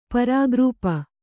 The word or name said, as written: ಪರಾಗ್ರೂಪ